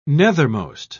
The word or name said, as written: nethermost